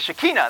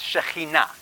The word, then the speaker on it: Shekhinah: The Shekinah, the Shekinah. That's not how you pronounce it. It's Shekhinah.